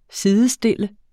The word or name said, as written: SIDESTILLEDES